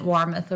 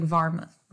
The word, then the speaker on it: Warmoth: Georg, and I know Warmoth isn't Warmoth over there. It's like Varmoth.